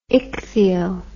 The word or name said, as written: ichthyo